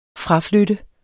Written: fraflytte

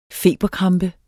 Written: FEBERKRAMPER

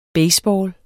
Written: baseball

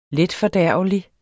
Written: letfordærvelig

